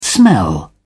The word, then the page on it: smell: British English